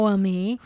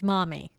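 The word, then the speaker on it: Maumee: Now, here's a story from Mommy, and that is how you pronounce it, people. It's Mommy. – Not Mowami. It's not Momi. It's Mommy. Got it? – Okay.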